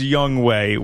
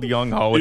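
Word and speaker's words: Younghoe: Oh, we know it's Young Way. We know, yes. But it's spelled Young Ho, and yeah. That's the way we're going to say it because that's hilarious. Young Way is not as funny as Young Ho, as you well know.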